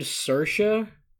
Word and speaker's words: Saoirse: Um, everyone named Saoirse, you gotta go.